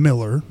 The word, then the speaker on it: Miller: But it's always been spelled M-A-Y-E-R. – That's Mayer. – Well, all right. My last name, Miller, the way I spell it, M-I-L-L-E-R. There's also M-U-E-L-L-E-R.